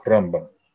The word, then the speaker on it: Рамбам: Рамбам всегда говорят с ударением на первый слог. Рамбам.